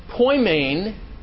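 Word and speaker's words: poimēn: And then Poimain, the, the E is a, is a long E sound, so it actually says A.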